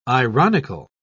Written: ironical

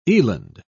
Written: eland

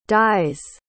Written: dyes

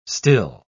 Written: still

